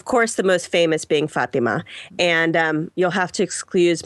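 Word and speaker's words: Fátima: I do come from Mexicans and I can only pronounce Latin words as if Fatima is, that's how they say it in Portugal. And Every now and then for my non-Hispanic friends, I'll you know, say Fatima. But I have to say that as a Portuguese woman, I do call it Fatima.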